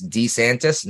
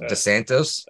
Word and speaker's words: DeSantis: It's DeSantis, not DeSantos.